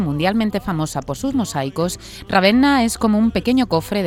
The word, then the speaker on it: Ravenna: que nos conducirán a descubrir la provincia de Ravenna.